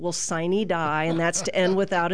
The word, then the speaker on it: sine die: It's like a sine die or something like that.